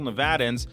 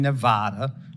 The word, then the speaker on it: Nevada: Point in Nevada. Okay, Nevada. Nevada, sir. Please say it correctly. It's Nevada.